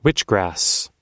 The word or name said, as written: witchgrass